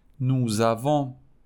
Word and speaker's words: nous avons: ولی اون اس که تلفظ نمیشه به واسطه یه حرف صدادار بعدیش تلفظ میشه و ما میگیم نوزوان ما داریم شما دارید ووزوه ووزوه